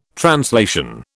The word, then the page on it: translation: British English